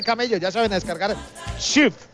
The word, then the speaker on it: Cheaf: Es Chief, C-H-E-A-F. – ¿Otra vez? – C-H-E-A-F. – Listo, así Chief.